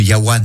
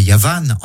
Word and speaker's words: Yavan: Plus tard, le mot Yavan, prononcé non plus Yawan mais Yavan en hébreu médiéval,